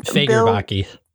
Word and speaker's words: Fagerbakke: And oh, Bill, how do you say his last name? – Fagerbakke. – Fagerbakke. – Yep.